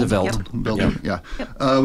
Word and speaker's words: The Veldt: So what we're going to talk about today, though, is the short story The Veldt, which came, am I saying that right? The Veldt? – The Veldt.